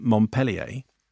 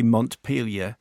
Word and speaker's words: Montpelier: That's why I just wanted to let you know that the email you received from Vermont in last week's show was not, in fact, from Montpellier, pronounced like the city in France, but from Montpelier. – You're kidding. – The capital of Vermont and the smallest state capital in the United States. – Really? – If you want to get it right, you say Montpelier. A few more to watch out for. It's Boise, Idaho. Not Boise, B-O-I-S-E.